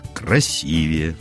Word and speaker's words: красивее: Ну, например, чем человек счастливее, тем выглядит красивее.